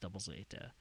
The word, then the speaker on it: Gundam ZZ: And then I started watching some other stuff because there is a third show in that original run of Gundam called Gundam Double Zeta, or Gundam ZZ, as you will see it written, but it's pronounced Double Zeta.